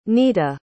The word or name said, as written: neeta